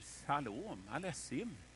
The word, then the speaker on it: shalom aleichem: Han säger shalom aleichem. Man ska uttala det så, det vet jag nu. Shalom aleichem, precis som i choklad.